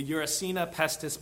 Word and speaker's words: Yersinia pestis: The Yersinia pestis bacteria.